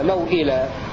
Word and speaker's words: موئلة: اتفق أهل الأداء عن ورش على قصر الواو الأولى التي بعد الميم في لفظ المؤولة في سورة التكوير وإذا الموؤودة سئلت، وقصر واو موئلة في سورة الكهف هكذا موئلة، هذا معنى قول الناظم وعن كل من الموؤودة تقصر وموئلة. والمقصود بقصر الواو في هذه الكلمات الثلاث سوآت والموؤودة وموئلة النطق بالواو من غير مد مطلقا مثل النطق بواو فوقكم من غير مد أبدا.